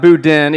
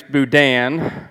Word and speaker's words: boudin: So I learned that it's not boudin, even though it's spelled boudin, it's boudin.